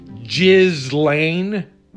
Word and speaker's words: Ghislaine: Again, let me just tell you, if a woman named Ghislaine, which is how it's pronounced in France, if a woman named Ghislaine asks you to come meet a bunch of men, don't do it.